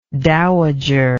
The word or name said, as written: dowagerism